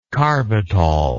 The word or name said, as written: carbitol